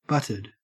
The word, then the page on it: butted: Australian English